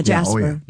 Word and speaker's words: Jasper: That's a hard J, though, right? – Yeah, Jasper. – Yeah, Jasper, Indiana, on Saturday, August 22nd.